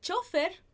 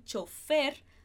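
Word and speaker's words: chófer: Em espanhol a gente pode falar el chofer, colocando acento, ou el chofer, sem colocar acento. Então, chofer, chofer, chofer, chofer, perfeito? A gente muda aí a sílaba mais forte, mas é a mesma coisa.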